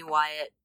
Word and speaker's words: Wyatt: And all I'll say, if you have some folks, some listeners that are interested in hearing this album, my name is Jaime Wyatt. I spell it like Jaime Wyatt. So that's J-A-I-M-E and mm-hmm. Wyatt like Wyatt Earp, W-Y-A-T-T.